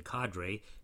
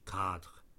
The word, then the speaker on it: cadre: The word comes from French cadre, which has no accent over the E at the end and is not pronounced with an A sound. In fact, the original pronunciation in English has a French ending but an English-sounding beginning. You can say a cadre of researchers and be speaking correctly.